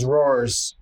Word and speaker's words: RAW: Yeah, the rolly papers that Chris is using now are known as Roars. Where? – In Queens, in Brooklyn, it's spelled R-A-W, but I know people that that word is raw, but roar. The way they say it, roar.